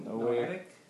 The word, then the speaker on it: Noahic: You're going to see the Noah, I can't remember how to say it. How do you say it, right? – Noahic covenant was, the sign was what? – Rainbow.